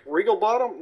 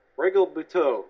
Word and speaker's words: Regalbuto: I'm not going to say the name right. Regalbuto. – Regalbuto? Regalbottom? No, Regalbuto. Let's say it.